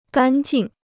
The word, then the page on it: 干净: gān jìng